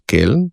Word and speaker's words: Köln: Dejansko ta in Ü, pravilo je, da se izgovori to v knjižnem jeziku kot oski e, se pravi Köln se bo izgovoril kot Keln in potem iz tega nastala Kelnska katedrala, medtem ko ü se izgovori kot i, torej München, Münchenski sporazum.